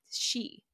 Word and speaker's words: sidh: And on the top of that list of evil creatures was the fairies, called the She, which is spelled S-I-D-H, by the way. She.